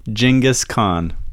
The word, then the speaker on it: Genghis Khan: Apparently it's always been Genghis Khan. We've just always been saying it wrong. Genghis Khan.